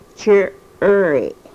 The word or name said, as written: chirry